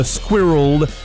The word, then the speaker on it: squirreled: Donc, un peu comme world en anglais, on a squirreled, c'est une seule syllabe.